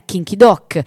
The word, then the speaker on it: Kim Ki-duk: Io al Corea Film Festival di Firenze ho scoperto che la pronuncia occidentale che più si avvicina probabilmente al giusto è Kim Ki-duk, e quindi la faccio mia, l'ho presa per oro colato.